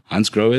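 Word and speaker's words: Hansgrohe: Am I pronouncing it correctly? – Hans Grohe is correct. Yes, I know there's quite a few ways for people to say the name, but Hans Grohe is good.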